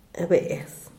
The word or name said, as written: beez